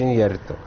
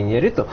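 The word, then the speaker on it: Iñárritu: которое, безусловно, является фильмом Иньярриту, правильно говорится, Иньярриту, а не Иньярриту.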